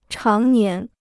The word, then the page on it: 常年: cháng nián